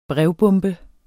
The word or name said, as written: brevbombe